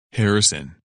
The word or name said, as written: Harrison